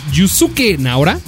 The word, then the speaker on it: Yusuke Naora: Yusuke Naora, ¿lo dije bien? – Sí.